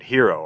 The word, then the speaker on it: Hiro: Put that out of your mind for now, because now we flip to the main hero of the game, who is ironically named Hiro, although it's spelled H-I-R-O.